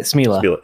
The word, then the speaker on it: Smela: Is your last name is it pronounced Smela or? – Yeah, you got it. Smela.